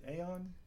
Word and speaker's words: Aon: Is it Aon? Is that how it's pronounced? – Sure. – That's how I'm going to pronounce it. – That's how we're pronouncing it, yes.